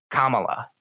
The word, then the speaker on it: Kamala: Now listen, it's not hard to pronounce. It's Kamala. It's really not that difficult.